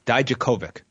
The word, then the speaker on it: Dijakovic: Dijakovic.